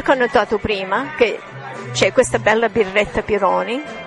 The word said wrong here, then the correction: Peroni: Peroni, aspetta, l'ho detto giusto. – Peroni. – Ma che... – Perfetto. – Che vergogna, non l'ho detto giusto. – Ma no, Peroni.